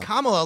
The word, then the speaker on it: Kamala: Kamala, like Pamela. Kamala yeah.